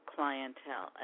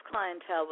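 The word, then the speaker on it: Klyntel: The group is named Clientele, an amazing, amazing band.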